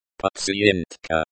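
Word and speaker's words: Patientka (пациентка): Patientka. Patientka. Hint. It sounds similar to the English word, but the T is silent.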